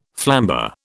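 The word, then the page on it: flambé: British English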